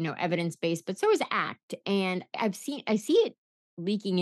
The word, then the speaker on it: ACT: Acceptance and commitment therapy, or ACT, is pronounced ACT because it's about acting towards your values.